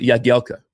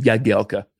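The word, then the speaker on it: Jagielka: How do you pronounce it? And he actually pronounced it Jajelka. – Yeah. Oh. – Say it again. – Yeah. Jajelka. – Jajelka? – But in England, they call him Jajelka. – Yeah. Yeah. But yeah, the correct pronunciation is Jajelka.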